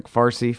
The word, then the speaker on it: Fars: if that's how you pronounce it, I think that's it, right? Like Farsi, Fars, right? Yeah, F-A-R-S.